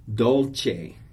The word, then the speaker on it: dolce: Dolce, the Italian word for sweet. It has the CH sound, even though it's a CE. Dolce.